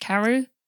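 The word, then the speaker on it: Karhu: And is it, how's the one with the K pronounced? Carhu? Yeah, that's it.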